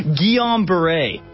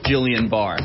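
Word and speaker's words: Guillain-Barré: Guillaume Barré. Apparently is how you pronounce Jillian Barr. It looks like Jillian Barr. That's what it looks like.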